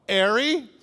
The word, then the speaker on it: Harry: One author suggests the key to understanding why Rowling might have used Harry is its Cockney pronunciation, right? Hello, Harry! Instead of Harry. And the silent H, Harry, sounds at least like the word air, H-E-I-R.